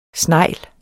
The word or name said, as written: SNEGLENES